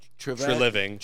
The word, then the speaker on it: Treliving: It sounds like traveling, like, or like Treliving. – Treliving. Is that like the shampoo I like to use? Isn't that the same tray, whatever?